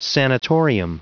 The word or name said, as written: sanatorium